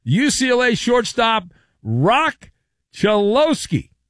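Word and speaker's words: Cholowski: Instead of correctly pronouncing the player's name, Chelowski, which is his name, Manfred pronounced his name Cholusky.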